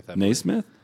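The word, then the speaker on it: NeSmith: He finished second place behind Matthew Naismith. Am I saying that right? – Naismith. Yeah.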